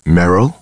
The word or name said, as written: Meryl